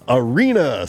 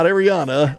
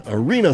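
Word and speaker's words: Aryna: Arena Sabalenka, not Ariana. Arena Sabalenka and Elena Rybakina, not Rybakina.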